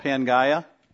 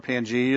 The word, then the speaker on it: Pangaea: Yeah, scientifically, yeah, it's called Pangaea, or Pangea you'll hear pronounced sometime.